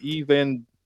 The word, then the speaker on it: Evangelion: How do you pronounce that word? – Evangelion. – Evangelion. Okay. Yeah. It looks like evangelical, but it's obviously not.